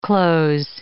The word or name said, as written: cloze